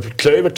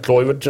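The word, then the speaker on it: Kluivert: Men holländska kan man, eh, var klövert där, det var någon som sa till mig, du kan uttala det på tre, fyra olika sätt eh, beroende på var du kommer ifrån i Holland. – Klövert, Kleifert, Kloivert.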